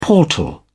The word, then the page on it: portal: British English